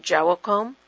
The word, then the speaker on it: Joachim: Joachim. And some people say Joachim, and it just depends on where you're raised, how it's said.